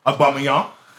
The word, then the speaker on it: Aubameyang: And also two strikers, Tammy Abraham and Aubameyang. That's how you pronounce it.